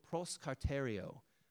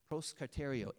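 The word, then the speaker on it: proskarterio (προσκαρτερέω): And the word is kind of a hard one to pronounce, but it's proskarterio. Proskarterio.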